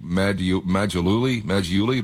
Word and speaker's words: Maggiulli: I believe the man's name was Nick Maggiulli. Do I have his name pronounced? – That's right.